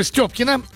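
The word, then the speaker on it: Стёпкина: Сейчас фамилия по мужу Степкина. Ударение на букву «Ё». А во всех документах пишут Степкина. И мне это не нравится, когда фамилию коверкуют.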